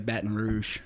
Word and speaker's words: Baton Rouge: Well, we just normally say Baton Rouge.